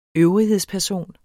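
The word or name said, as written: ØVRIGHEDSPERSON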